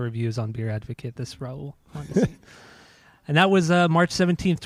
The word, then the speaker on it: Raul Mondesi: How do you pronounce the name, Jeff? – Raul Mondesi.